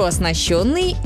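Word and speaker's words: оснащённый: Чтобы запомнить, можно зацепиться за ритм. Завод хорошо оснащенный, и темп уже наращенный. И дальше все становится проще. Если есть «ё», ударение уже никуда не убежит.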